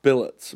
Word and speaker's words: Bilott: Yep, it's Robert Billet, B-I-L-O-T-T. – Yeah. All right. Yeah. What did I say? Spillet. – I don't know. That's what you said. – I think I got the last syllable right, but not the first.